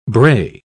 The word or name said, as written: brae